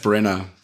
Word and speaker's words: Branagh: Einfach nur Kenneth Branagh.